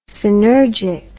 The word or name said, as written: synergic